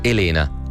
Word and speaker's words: Elena: Si chiama Elena, con l'accento sulla seconda E.